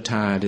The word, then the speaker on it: tired: And Tennyson says that the word tired is neither a one-syllable word nor a two-syllable word, but a dreamy child of the two. You have to hover between the two different ways of pronouncing it when you hear it, and you have to imagine that if you're reading a poem.